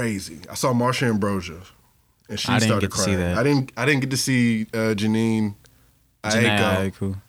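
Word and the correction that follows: Jhené: I say her name so wrong. – Yeah, I know. – Name is Janae Aiko? – Yeah. – Okay. I'd be calling that motherfucker Janine.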